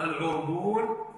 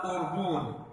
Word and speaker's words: عربون: وهذه الكلمة لها عدة لغات في كلام العرب، يعني يقال العربان ويقال أيضا العربون، وهذه الكلمة المشهورة عندها بيع العربون يعني بضم الباء بعدها واو مدية، بعدها واو مدية. وقد تنطق هذه الكلمة أيضا بالهمس بدل العين، بالهمس بدل العين، يقال أربون، أربون يعني بدل عربون يعني تستبدل العين همزة، يعني يقال أربون وأربان، ويقال أيضا عربون، عربون يعني بفتح العين والراء معا، بفتح العين والراء معا عربون.